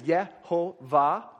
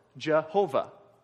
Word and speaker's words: Jehovah: Okay, so when you hear people say Yahweh or Jehovah, that's just two different ways of pronouncing the same name, which is this right here. And Yahweh is probably closer to the right thing.